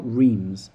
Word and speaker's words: Reims: It's basically around the town of Reims, R-E-I-M-S. I think I'm pronouncing that correctly. It's not Reims. I think it's Reims.